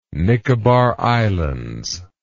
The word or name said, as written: Nicobar Islands